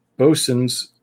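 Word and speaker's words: boatswain's: This is called a bosun's whistle, or a bosun's call, or a bosun's pipe. It is spelled like it would be pronounced boatswain, but it's bosun's call.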